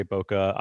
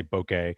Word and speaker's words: Bokeh: You know, there's a pretty broad range of, I think, acceptable pronunciations. People say Boca. I think I usually say bokeh. They're all good.